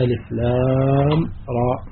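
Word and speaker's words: الر: وهذه السورة ابتدأت بهذه الحروف المقطعة: ألف لام راء، تنطق ألف لام راء.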